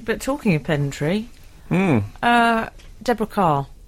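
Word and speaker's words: Kerr: Well, that doesn't surprise me, because what you wouldn't know is that Deborah Carr made it clear that her surname should always be pronounced the same as Carr, as in the vehicle.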